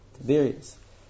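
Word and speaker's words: Tiberias: How do you say Tiberia? Tiberius? Tiberius. And Yehuda's wife was a good, modest wife.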